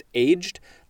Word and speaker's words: aged: This word is aged, A-G-E-D. Most people would probably want to pronounce it aged. But actually, that does look like it is an approved pronunciation, but I think usually it would be pronounced aged.